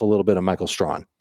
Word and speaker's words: Strachan: His name is Michael Strawn, but it looks like his last name is pronounced Strachan. But it's actually Strawn.